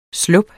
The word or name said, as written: slup